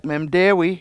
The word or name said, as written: memdEEwi